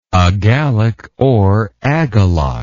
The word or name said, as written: agalloch